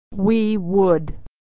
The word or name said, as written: we would